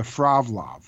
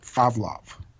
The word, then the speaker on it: Frovlov: It's called the Fravlov. – Spell that for me. – F-R-O-V-L-O-V. Fravlov.